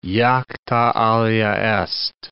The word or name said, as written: jacta alea est